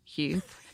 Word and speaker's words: Huth: Well, they can find me on Facebook with my name, Carrie Huth. – Yeah. Not Kirkwood. – That is spelled H-U-T as in Tom, 8.